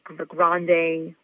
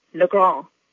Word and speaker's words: Legrand: In New Orleans, where my dad is from, they say it Legrand, which is how you would pronounce it in French, but I'm from Texas, so I pronounce it Legrand.